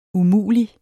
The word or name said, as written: umulig